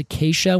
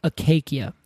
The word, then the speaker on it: acacia: But the funny thing is, these trees that literally come from the Greek word for thorn, and now we pronounce it as acacia, when it, back then I think because they did hard C's, would have been acacia.